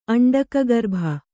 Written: ಅಂಡಕಗರ್ಭ

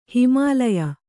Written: ಹಿಮಾಲಯ

